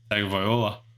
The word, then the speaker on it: Tagovailoa: Tagovailoa. – Tagovailoa, officially healthy heading into the new season after picking up his fifth-year option.